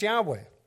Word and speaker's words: Yahweh: It says several early Greek writers of the Christian church testify, where they witnessed, that the name was pronounced Yahweh.